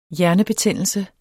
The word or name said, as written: hjernebetændelse